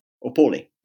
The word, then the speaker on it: Opole: Opole.